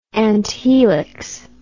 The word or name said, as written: anthelix